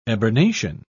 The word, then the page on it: eburnation: British English